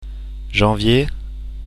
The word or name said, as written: janvier